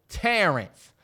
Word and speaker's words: Terrance: I was like, Terrance. And they was like, Tez, no, Terrance.